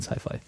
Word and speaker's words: SYFY: Haben auch alle irgendwie blöd gefunden, aber jetzt, im Moment heißt der Kanal SYFY ausgesprochen weiterhin Sci-Fi.